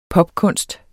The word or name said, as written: popkunst